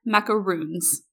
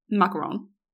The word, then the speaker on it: macaron: And my friend just looks at her and she goes, it's pronounced macaron. She goes, no, they're macaroons. And my friend goes, no, they are macarons. It's R-O-N and it's French.